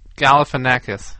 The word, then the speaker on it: Galifianakis: Galifianakis.